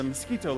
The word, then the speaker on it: Miskito: And that's spelled M-I-S-K-I-T-O, Miskito.